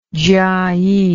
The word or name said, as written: Jiayi